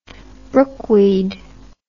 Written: brookweed

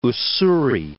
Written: Ussuri

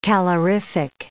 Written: calorific

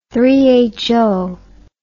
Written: three-handed

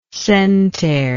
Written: centare

